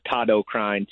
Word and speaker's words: Todd Okrine: But it's under Todd O'Krine, T-O-D-D-O-K-R-I-N-E. And a little backstory there. I used to spend a lot of time in Japan, and that's how they pronounce my name.